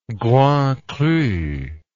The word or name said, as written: grand cru